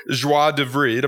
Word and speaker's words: joie de vivre: But also joie de vivre. Am I saying that right? – Joie de vivre. Just say vivre.